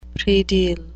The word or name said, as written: predial